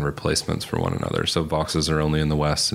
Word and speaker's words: Vaux: Well, first of all, it's Vaux's swift. – Thank you. It was V-A-U-X, and I went for the fancy pronunciation. – Everybody does. – Sorry. – But the guy's last name was Vaux, okay.